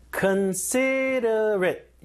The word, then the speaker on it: considerate: 아, 발음이 어렵네요. considerate. 약간 리을처럼 바뀌어요. 이게 flap sound. 설탕음.